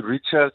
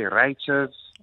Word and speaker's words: Rytious: So I was like, how about I change the spelling to R-Y-T-I-O-U-S? And then that's how the name came about. People liked it. Some people call me Rytious, some say Rytious.